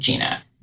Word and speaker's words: Gina: It's spelled like French and it's pronounced Italian, but it's white sounding, Gina.